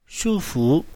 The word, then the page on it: shu1fu2: mandarin